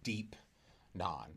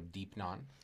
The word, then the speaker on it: deipnon: Just to be clear, you're saying deep non. – Yes. – Right? So D, I believe it's DEIPNON. – Yes. – Is how we would spell that in English. – Yes. – Okay, so deep non.